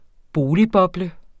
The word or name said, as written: boligboble